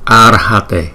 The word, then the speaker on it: Arhate: Cái chữ R này chúng ta đọc là ra, ra. Ar, Arhate, Arhate.